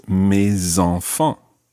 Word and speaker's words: mes enfants: And it ends in an S, so normally the S is silent, but when it's followed by a vowel, it becomes mes, in this case, mes enfants.